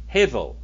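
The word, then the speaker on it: Hevel (הבל): Unto Abel. Hevel is how you say it.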